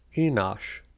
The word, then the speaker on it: Enosh: Number 3 in the order, Enos, or sometimes pronounced Enosh.